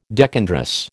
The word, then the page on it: decandrous: American English